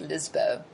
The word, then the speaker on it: Lisboa: Is that how they're pronouncing it, Lisboa? – Yeah. Well, that's how it's spelt, too. – Oh, okay. So I would imagine that your Brazilian friends would know that it was pronounced Lisboa.